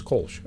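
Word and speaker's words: Kölsch: And I always pronounce this wrong. What is it? Kolsch? – It's a Kolsch.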